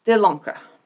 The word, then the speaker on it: de Lancre: Oh, I didn't pronounce that right. De Lancre. I hate when it has to end with the guttural, but I listened to like 5 pronunciations of it, and they all had that.